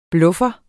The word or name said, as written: bluffer